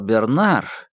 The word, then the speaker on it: Бернар: «Возможно, вам будет приятно услышать, что Бернар уже более-менее пришел в сознание, хотя вы сломали ему 2-3 ребер. – «Бернар?» — с удивлением переспросил Курц, сделав ударение на последнем слоге, на французский манер, как и Гонзага.